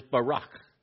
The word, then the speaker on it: barak: The Hebrew word is barak. Can you say that? No, you got to say it like you got snot in your throat.